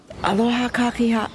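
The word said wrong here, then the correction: Aloha kakahiaka: Gotta pronounce it correctly. Aloha kākahi'aka.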